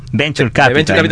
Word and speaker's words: Venture Capital: O sea, coméntanos un poco acerca de Adventure Capital. ¿Lo dije bien? – ¿Sí? Bueno. – Venture Capital.